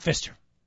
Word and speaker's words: Fister: By the way, it is Fister without the silent P.